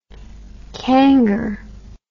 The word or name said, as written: kangar